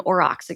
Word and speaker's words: aurochs: So like a feral pig, or like you can have a feral cow, but that cow will not necessarily become an aurochs again, the predecessor of cows. – Aurochs. I don't know about aurochs. That's cool. – Am I pronouncing that correctly? – Yeah, that sounds awesome.